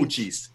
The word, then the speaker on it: Uchis: It is, I believe, her name is Callie Uchis? U-C-H? – Uchis. – Uchis? Thank you.